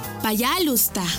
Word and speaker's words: Пожалуйста: Пожалуйста. ¿Cómo? Пожалуйста. Una vez más. Пожалуйста. Y se escribe Payalusta. P-A-Y-A-L-U-S-T-A.